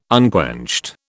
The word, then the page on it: unquenched: British English